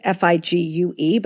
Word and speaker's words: Figue: It's spelled with a French spelling. So it's Figue, but it's actually pronounced Fig.